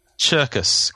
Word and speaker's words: Czerkas: Cherkis, because it's spelled C-Z-E-R-K-A-S. Cherkis. Stefan Cherkis.